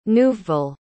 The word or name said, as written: neuveville